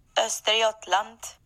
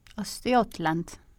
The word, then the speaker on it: Östergötland: Östergötland. – Östergötland.